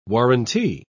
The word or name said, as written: warrantee